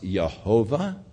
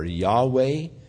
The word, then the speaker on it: Yahweh: Now, whether or not the name was pronounced Jehovah or Yahweh, we're really not sure. Most scholars think that it was Yahweh.